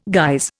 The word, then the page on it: guise: Indian